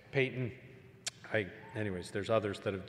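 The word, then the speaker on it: Paton: Payton. It's not Patton, it's Payton.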